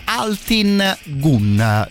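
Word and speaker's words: Altın Gün: Loro si chiamano Altin Gun, li eh, pronuncia esattamente come si scrive, così se magari li volete andare a cercare alla volo, ecco, magari la cosa diventa un eh, po' più facile.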